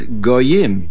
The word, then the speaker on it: Goyim: The kri, the pronunciation, is Goyim, nations.